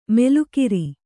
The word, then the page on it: ಮೆಲುಕಿರಿ: melukiri